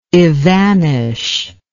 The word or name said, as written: evanish